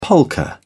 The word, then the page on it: polka: British English